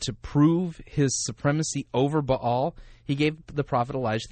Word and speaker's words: Baal: And what a lot of people miss in this story, by the way, this little side note, is that that Baal, if you want to pronounce it properly, Baal was supposedly the lord of the sky.